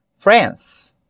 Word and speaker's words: France: Porém, falando inglês, contexto de língua inglesa, a palavra France ela é monossílaba, só tem uma sílabazinha, certo? Então vamos lá, France, France. E assim nós concluímos o nosso trabalho de hoje, desse episódio, com o nome dos países e as suas respectivas nacionalidades.